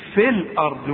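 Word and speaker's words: في الأرض: انما دلوقتي نقول في الارض وكأن الياء غير موجودة، انما تثبت اذا وقفت عليها،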